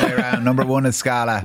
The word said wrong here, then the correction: Sacla: I keep pronouncing it wrong. That's my fault. It's Sacla. – Sacla, not Scala.